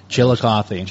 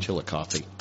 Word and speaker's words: Chillicothe: It's Chillicothe. – It ends with a T-H-E. Right? – Yeah. Chillicothe. – Chillicothe.